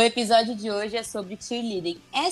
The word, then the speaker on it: cheerleading: Se você é quiser falar com sotaque americano, é cheerleading.